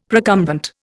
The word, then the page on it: procumbent: Indian